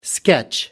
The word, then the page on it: sketch: American English